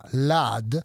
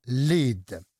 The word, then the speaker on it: lead: Oltre a piombo, pronuncia lad, può significare anche guida, pronuncia lead.